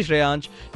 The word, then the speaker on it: Shreyansh: आरजी श्रेयांश dash red FM के नाम से एक हैंडल है मेरी, नाम की स्पेलिंग SHREYANSH डैश लाल के रेड एफएम डालना, you will get to know.